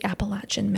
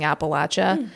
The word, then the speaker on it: Appalachia: We're going to the Appalachian Mountains.